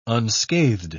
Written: unscathed

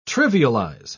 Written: trivialize

